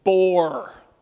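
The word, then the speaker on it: Bore (בור): The rendering of a Hebrew word, y'all want to know how this is pronounced? This is hilarious. Bore. Bore. It's a bore in the cistern.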